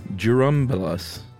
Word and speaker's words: Churumbelas: Suggestion on how to pronounce it. Churumbelas. – Well, is it right. A DJ? – It's a CH pronounced like a JU, he says.